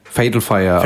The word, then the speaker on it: Fatal Fire: Fatal Fire, also Fatal Englisch Fire. Ausgesprochen.